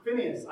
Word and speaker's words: Phinehas: When Phinehas comes to them, Phinehas, I did a Hebrew thing. Phinehas is how you say it in Hebrew.